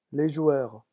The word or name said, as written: les joueurs